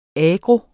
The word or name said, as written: agro.